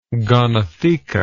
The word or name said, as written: gonotheca